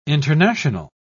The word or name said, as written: international